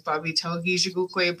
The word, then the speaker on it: Baabiitawigiizhigookwe: My name is Baabiitaw, and my full name is Baabiitawigiizhigookwe, but I try to be easy on people, and I go by Baabiitaw.